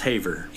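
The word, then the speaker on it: Havre: Might butcher the name here. Haver? I'm thinking it's Haver. – Yeah.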